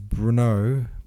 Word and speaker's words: Brno: It seems like the Brunn we're talking about is Brno, present day Czechia, which is also called Brunn in German. Do I have that right? Yes, it is. It's that one that's spelled B R N O.